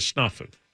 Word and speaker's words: snafu: Is it snafu? – It is snafu. – Oh, yes. From where I come from, we pronounce it snafu. – You put an O in there instead of an A.